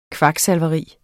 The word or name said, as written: KVAKSALVERI